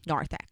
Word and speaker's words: narthex: But yeah, the official term, I believe, is narthex. – Narthex. – Yeah. – Narthex.